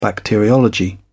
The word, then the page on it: bacteriology: British English